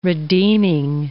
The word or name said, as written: redeeming